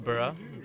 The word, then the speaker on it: Bhura: My name's Burra. Started off, is that it? Did you say my name? Burra. Bhura. – However you want to do it, man. Spelling. – The H is silent, Burra.